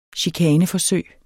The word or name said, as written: chikaneforsøg